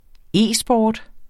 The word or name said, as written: e-sport